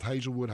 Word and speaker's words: Hazelwood: Those of you who have followed not only PSR but high school football are so very familiar with Hazelwood High School.